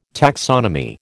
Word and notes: taxonomy: American English

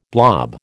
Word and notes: blob: American English

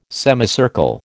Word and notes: semicircle: American English

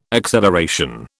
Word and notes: acceleration: British English

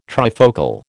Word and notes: trifocal: American English

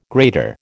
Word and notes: grater: American English

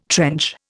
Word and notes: trench: Indian

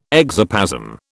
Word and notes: exoplasm: British English

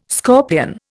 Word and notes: scorpion: Indian

